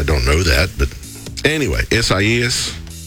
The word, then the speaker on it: Isaias: The storm is called Isaias. It's spelled I-S-I-A. I done messed it up. I-S-A-I-A-S. Isaias.